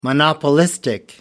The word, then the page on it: monopolistic: American English